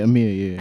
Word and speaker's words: Amia: But I think the removal of Amia, am I pronouncing it properly? – Yeah, Amia, yeah.